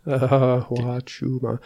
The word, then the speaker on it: Huachuma: But the best place to start is called Huachuma. – Spell that. – I hate spelling. Huachuma. H-U-A-C-H-U-M-A.